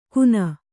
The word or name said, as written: ಖೂನ